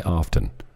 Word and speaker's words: often: So they avoid and promulgate the propaganda endlessly, feeling that if they tell a lie often enough, often, I say often, but the T is silent.